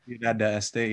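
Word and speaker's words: Ciudad del Este: like the Ciudad de Este. I'm going to pronounce that wrong. – Ciudad de Este, yeah. – Yeah, Ciudad de Este, thank you so much.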